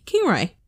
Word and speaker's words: Kingaroy: I may have said that wrong. Kngaroy. Kingaroy. The Big Peanut.